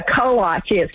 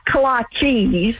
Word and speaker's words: kolach: We know that it's kolach, but if you want to pronounce it kolachi.